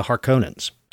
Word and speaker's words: Harkonnen: There are two of the many noble houses that are bitter rivals, the Atreides and the Harkonnens. I think that's actually Harkonnen. I always mispronounce that.